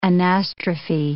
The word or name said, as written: anastrophe